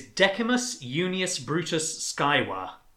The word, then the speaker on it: Decimus Junius Brutus Scaevola: It is Decimus Junius Brutus Scaevola.